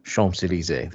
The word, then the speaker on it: Champs-Élysées: And Les Champs-Élysées was mentioned by The Chef. I probably butchered that pronunciation, but Champs-Élysées, there we go, in France.